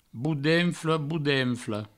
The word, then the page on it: bodenfle, bodenfla: boudenfle, boudenflo